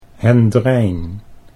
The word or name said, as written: Hendrijn